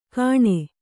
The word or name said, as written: ಗಣೆ